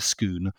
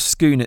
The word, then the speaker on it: Scone: But the Stone of Scone itself, which is spelt S-C-O-N-E, so almost like how you'd pronounce scone. – Scone or scone, but the Scots say scone.